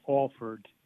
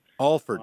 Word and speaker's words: Alford: Actually, the locals call it Alford. – Alford. – Yes, but it looks like Alford, though.